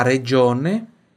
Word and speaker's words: regione: La Calabria è la regione che costituisce la punta dello stivale. Vamos por partes aqui falar das pronúncias. La Calabria é fácil, né? É la regione, regione, GI som de DGI, regione.